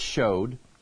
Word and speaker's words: shewed: And how I kept back nothing that was profitable unto you, but have showed you and shewed you. I need to start pronouncing that right. But we know it's showed.